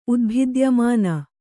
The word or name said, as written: ಉದ್ಭಿದ್ಯಮಾನ